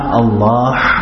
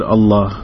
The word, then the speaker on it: Allah: Ha is soft, like in the word Allah. Not Allah. Allah.